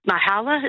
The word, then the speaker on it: Myhala: The latest celebrity who went out, Myhala, is that how you say her name? – Yes, correct.